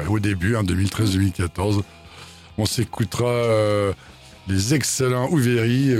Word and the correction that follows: Overfree: Ouveri, euh, non, Overfree, pardon, on dit, hein, je, me, prononce ah mal, Overfree,